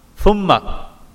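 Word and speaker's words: Thumma (ثم): So for example, you say Thumma with a Tha. Thumma. Kalla sawfa ta'lamun. Thumma means then.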